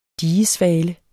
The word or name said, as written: digesvale